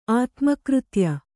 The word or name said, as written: ಆತ್ಮಕೃತ್ಯ